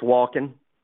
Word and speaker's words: Wolken: Bird's eye view, that what I kept thinking about, and I am not Dan Wolkenen. Is that Wolken? Dan Wolken? Is that how you say it? USA Today guy who everybody hates? – I'd say it's Walken. Yeah, okay. Walken.